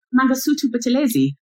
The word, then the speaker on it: Mangosuthu Buthelezi: Mangosuthu Buthelezi.